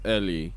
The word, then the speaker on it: Eli: the name is Eli. The way they pronounce it is Ellie.